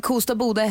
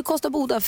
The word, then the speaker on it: Kosta Boda: Jag var nämligen i Kosta Boda i helgen. Resten av Sverige säger ju Kosta Boda felaktigt. Kosta Boda säger man ju, det vet ju alla.